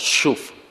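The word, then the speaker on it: shuv (שוב): 转离这个字原文读作 shuv，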